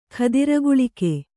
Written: ಕುದುರೆಗಾಳೆಗ

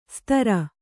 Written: ಸ್ತರ

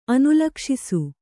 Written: ಅನುಲಕ್ಷಿಸು

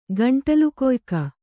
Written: ಗಂಟಲುಕೊಯ್ಕ

